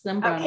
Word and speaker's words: Zambrano: Yes, Zambrano.